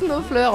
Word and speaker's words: Knopfler: Parce que alors c'était Schnaufer. On s'est dit comment on le prononce en vrai, quoi. – Mark Knopfler. – Mark Knopfler.